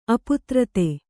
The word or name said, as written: ಅಪಾತ್ರತೆ